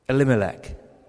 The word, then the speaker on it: Elimelech: Elimelech.